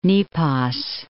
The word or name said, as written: Nepos